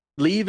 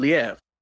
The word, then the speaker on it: Liev: Like leave is not leave, it's Liev. Say I didn't know that. Yeah, I didn't know it's Liev. Liev Schreiber.